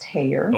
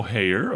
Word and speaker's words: Heyer: It's actually pronounced Heyer. – Oh, Heyer. Okay.